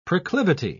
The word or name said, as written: proclivity